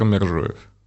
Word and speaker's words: Мержоев: Ну, Захар Мержоев.